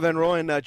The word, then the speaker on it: Van Royen: Robert Van Royen joins us.